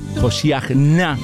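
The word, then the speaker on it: Hoshia na: porque el significado de la palabra Hoshiachna sería más o menos el hebreo, se pronunciaría más o menos así, de esa forma, significa sálvanos ahora.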